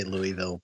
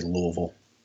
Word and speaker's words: Louisville: Well, you know, I say Louisville, but the people back home would say Louisville.